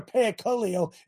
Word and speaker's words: parakaleo: These English words come from the original Greek language, parakalo. I think I didn't say it right. It's a compound word comprised of the preposition para and the verb kaleo. Para meaning to come alongside, while kaleo means to call. Together, parakaleo is an emphatic verb in the first person singular that means to call alongside and urge.